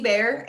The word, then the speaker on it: Baehr: Personal account is Lucy Bear, and my last name is incredibly hard for people, but it's B-A-E-H-R.